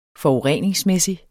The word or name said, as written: forureningsmæssig